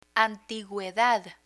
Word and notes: antiguedad: spanish

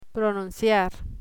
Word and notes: pronunciar: Spanish

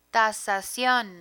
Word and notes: tasasion: Spanish

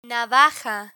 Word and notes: navaja: Spanish

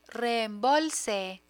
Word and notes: reembolse: Spanish

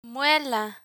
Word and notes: muela: Spanish

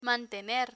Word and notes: mantener: spanish

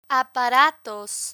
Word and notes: aparatos: spanish

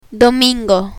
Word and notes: domingo: spanish

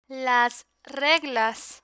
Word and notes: las reglas: Spanish

